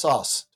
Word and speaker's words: Szasz: Thomas Szasz. Thomas Szasz, yes. He's Hungarian. Yeah, S-Z-A-S-Z, but I have it from the man's own mouth. It's just pronounced Szasz. – Szasz.